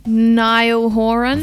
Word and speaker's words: Niall Horan: Niall Horan.